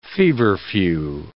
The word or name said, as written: feverfew